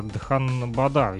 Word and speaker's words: Дханбада: А, наш любимый УГНТУ и Индийский техно- технологический институт города Дханбада, если я правильно назвал ударение. Да, да.